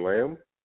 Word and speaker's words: Lam: This is your host, and today I am being joined by my guest, Jordan Lamb.